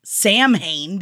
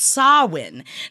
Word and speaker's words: Samhain: Now, Samhain, yes, a lot of people think that it's pronounced Samhain because that is how it's spelled. – It sure is. – But it is not how it is said. It is pronounced Samhain.